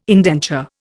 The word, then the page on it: indenture: Indian